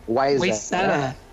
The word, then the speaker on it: Wayzata: Is it Wayzata? – Wayzata.